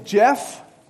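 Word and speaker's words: Geoff: Well, my name is Jeff.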